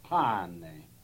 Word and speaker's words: pane: And they'll ask you, how do you say bread in Italian? And we practiced how to say it, pane.